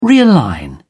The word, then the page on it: realign: British English